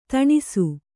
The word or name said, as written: ದಿನಸಿ